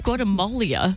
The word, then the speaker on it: Guatemala: Guatemala.